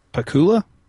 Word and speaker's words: Pakula: Well, I think his, you know, filmmaking hero is Pakula.